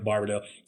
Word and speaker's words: Barbado: Joe being a very common name, and Barbado or Barbado, as it should be pronounced, but I go with Barbado, just means bearded in Spanish.